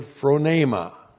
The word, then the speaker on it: phronema: In verse 6, the key word in verse 6 is the word phronema. Phronema, P-H-R-O-N, long E-M-A, phronema.